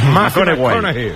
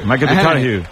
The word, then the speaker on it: McConaughey: Hay que ir corrigiendo la pronunciación. – McConaughey. – McConaughey.